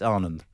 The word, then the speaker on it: Anand: Usually I don't care and everyone says Anand, but it's wrong. It's Anand.